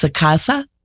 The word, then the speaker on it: SACASA: And is that pronounced SACASA? – It is, yes.